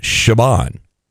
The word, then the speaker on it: Siobhan: It's one of the there's a singer named it, but I never could figure out how to pronounce it. And finally, it stuck, but it's Siobhan. But I think it's French. – Siobhan. – But it looks like Siobhan or Siobhan, but Siobhan.